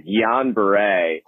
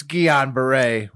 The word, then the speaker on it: Guillain-Barré: Believe Guillain-Barré. – Hey, fellas. I'm the surgery resident at UVA that listens to the show. I'm pretty sure it's pronounced Guillain-Barré.